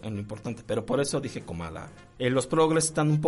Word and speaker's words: Kamala: Este, de hecho se pronuncia Comala.